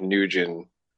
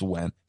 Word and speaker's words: Nguyen: But yeah, it's Wen.